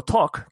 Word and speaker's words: talk: Eh, talking viene del verbo talk, que es hablar. Eh, se, escribe con L, pero se pronuncia sin la L.